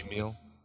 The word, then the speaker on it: Richard Mille: A Richard Mille? – Richard Mille? – I don't know what that is. – Mille. – It's probably a watch. It's spelled like Mille and Milbourne, the card game Milbourne.